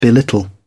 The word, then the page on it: belittle: British English